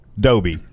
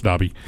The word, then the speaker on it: Dobby: I like to think that's a long O. Dobby. – No, it's Dobby. Don't you remember Dobby the house elf in Harry Potter? – Yeah, I do.